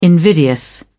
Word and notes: invidious: British English

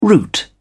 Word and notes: route: British English

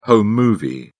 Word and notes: home movie: British English

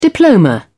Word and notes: diploma: British English

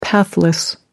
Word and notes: pathless: American English

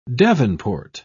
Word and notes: davenport: British English

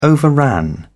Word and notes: overran: British English

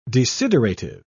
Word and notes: desiderative: British English

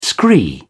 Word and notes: scree: British English

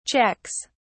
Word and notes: checks: British English

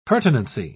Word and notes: pertinency: British English

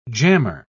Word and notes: jammer: British English

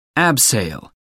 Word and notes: abseil: American English

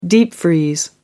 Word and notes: deep-freeze: American English

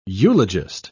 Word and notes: eulogist: British English

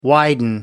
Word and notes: widen: American English

